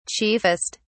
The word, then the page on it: chiefest: British English